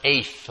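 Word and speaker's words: eighth (עת): The Hebrew word is eth. It's E-T-H. Pronounced eighth, like an A.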